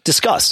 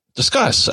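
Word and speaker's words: Disqus: And I've seen there's a comment or a discussion service, Discus. – Discuss. – Discus. – Yeah. – Discuss. Oh, that's how you say it.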